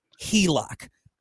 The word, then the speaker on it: HELOC: And that is HELOC. I said it right this time.